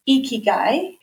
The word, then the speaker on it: Ikigai: Basically, it's called Ikigai. It's spelled I-K-I-G-A-I. – Okay. – Ikigai. And yes, we had to Google translate many different versions of how to say it properly.